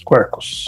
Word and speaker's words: Quarkus: A pronúncia certa é essa. É isso mesmo, quarkus.